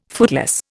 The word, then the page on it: footloose: Indian